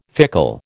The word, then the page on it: fickle: American English